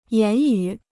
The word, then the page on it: 言语: yán yu